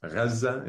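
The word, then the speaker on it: Heza (غزة): When you say Heza, is that actually a Arabic pronunciation of Gaza? – Yes. Heza is how we say Gaza. Yeah, that's correct.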